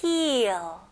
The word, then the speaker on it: heal: 여기서는 EA 발음은 E 발음이잖아요. 근데 조금 더 길게 해주세요. 힐링처럼 힐 이렇게 짧지는 않아요. 영어로는 heal 이렇게 고무줄 당기는 느낌으로 heal. heal. Okay, very good. 이 E 발음을 잘 해주셔야 됩니다.